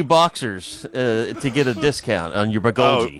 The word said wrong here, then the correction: bulgogi: That's actually pronounced Bulgogi.